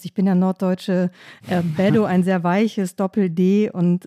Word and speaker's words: Beto: Beto O'Rourke ist der demokratische Kandidat um das Gouverneursamt heißt aber eigentlich Beto, also eigentlich mit einem harten T.